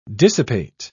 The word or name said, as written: dissipate